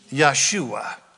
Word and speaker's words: Yeshua: How do you say Joshua in Hebrew? Yeshua.